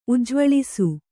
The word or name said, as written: ಉಜ್ಜ್ವಲಿಸು